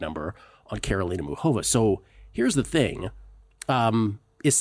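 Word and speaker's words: Karolina Muchová: Karolina Muchova is the correct way to pronounce that, by the way.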